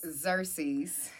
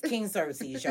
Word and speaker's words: Xerxes: King Zacharias. – Xerxes. – King Xerxes, y'all.